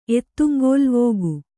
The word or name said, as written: ಎತ್ತುಂಗೋಲ್ವೋಗು